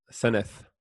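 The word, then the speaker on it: Senedd: Senate or Seneth? – Seneth. – Interesting. Yeah.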